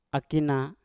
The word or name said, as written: akina